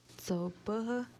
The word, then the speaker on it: so buha: So buha is how you say hair in Nuwa. So buha, so buha.